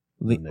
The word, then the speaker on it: Linnea: She's at Leanna. Am I saying it right? Leanna? – Linnea. – Linnea.